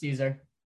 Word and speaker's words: Szczur: And Matt Szczur, am I pronouncing it correctly? Yep, it's Szczur.